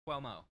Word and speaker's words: Cuomo: From Forbes, CNN has fired its highest-rated primetime anchor, Chris Cuomo, which I don't know why I can't pronounce that. – Cuomo,